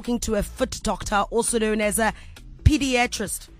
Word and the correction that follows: podiatrist: Did I say that right? – Podiatrist. Foot doctor.